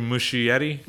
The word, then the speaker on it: Muschietti: How do you pronounce the director's last name? – Andrew Muschietti.